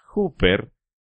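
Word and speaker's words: huper (ὑπέρ): Karl Barth dijo en una ocasión que la palabra más importante en todo el Nuevo Testamento es una pequeña palabra griega que se pronuncia huper, que traducida del griego significa a nombre de o por alguien.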